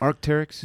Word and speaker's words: Arc'teryx: Arc'teryx? – Yeah.